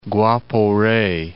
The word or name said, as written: Guapore